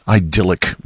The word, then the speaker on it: idyll: It's one of a few idyllic moments I'll have for you tonight. Idyll, or as the English pronounce it, idyll.